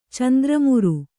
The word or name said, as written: ಚಂದ್ರಮುರು